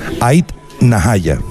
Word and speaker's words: Ait Nahaya: y estamos con el grupo Ait Mahaya. Creo que lo he dicho bien. Ait Mahaya. No. Ait Nahaya.